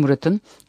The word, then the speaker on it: Murat: Yıldızının bir türlü barışmadığı dış haberler şefi, Türkiye'yi yakından izlediğini ve konusuna hakim olduğunu göstermek isteyerek, toplantıda konuşacağı bildirilen Monsieur Murat'ın ilginç siyasal kişiliğine rağmen mi diye sordu. Murat'ı Fransızca okunuşuyla Mura olarak telaffuz ediyordu. İnadına Murat telaffuzunun üstüne basa basa, "Evet, Monsieur Murat'ın ilginç olarak nitelediğiniz kişiliğine rağmen," dedi.